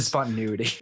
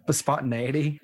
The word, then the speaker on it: spontaneity: Oh, did I say that wrong? – It's a spontaneity. – Is that not how you say it? Spontaneous, wait, what? – Spontaneity. – That's how you say it? – That's how I say it. – Spontaneity. Where's the A in the normal word? Spontaneous.